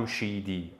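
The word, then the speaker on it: Jamshidi: Und auch mein Nachname Yam Yamshidi. Also Yam und dann Shi und dann Di. So spricht man meinen Namen aus.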